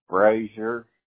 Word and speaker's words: Brasher: It's pronounced Brazier. It's B-R-A-S-H-E-R. – B-R-A-S-H-E-R.